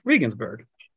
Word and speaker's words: Regensburg: Regensburg. – I like that one, boys. – So you were in Regensburg, and I'm not sure if prior to the WBC, I'd ever heard of Regensburg.